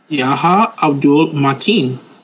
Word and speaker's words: Yahya Abdul-Mateen: What D are you pronouncing in there? It's a T. – Yahya Abdul-Mateen.